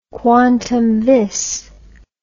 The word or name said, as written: quantum vis